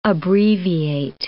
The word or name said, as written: abbreviate